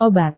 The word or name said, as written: obat